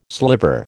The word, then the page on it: sliver: American English